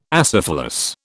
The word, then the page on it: acephalous: British English